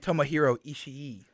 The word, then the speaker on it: Tomohiro Ishii: Versus the Elite, Eddie Kingston, Alex's favorite wrestler, and Tomohiro Ishii.